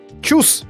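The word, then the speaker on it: Tschüss: Чус, вы говорите.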